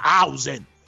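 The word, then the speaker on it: Huijsen: Ieri non me l'avete fatti gli auguri a Oisen, anzi a Hausen, come ha detto che si pronuncia giustamente Camillo, che mi sta diventando un po' come Franchi di Tutto Sport, vuole le pronunce corrette.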